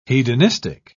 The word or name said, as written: hedonistic